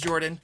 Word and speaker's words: Jorphdan: My name is Jorphdan, with a silent ph in the middle, and I am joined always by my wonderful co-host Sir Lucien over at Sir Lucien Gaming.